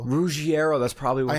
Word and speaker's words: Ruggiero: I've been pronouncing it Ruggiero, but it's Ruggiero.